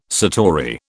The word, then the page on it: satori: British English